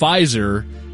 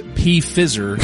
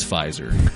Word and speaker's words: Pfizer: However, one time during a lecture, he mispronounced Pfizer as P-Fizzer, and I felt so royally vindicated.